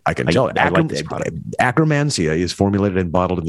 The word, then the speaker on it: Akkermansia: Akkermansia. – Well, I was just leaning into it, Rob. Thank you very much. Let's do it together. 3, 2, 1. Akkermansia.